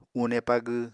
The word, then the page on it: une pagw: Apatani